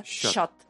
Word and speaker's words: чат: Пишется чат, читается щат. Татарша. Щат.